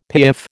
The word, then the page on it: piaffe: American English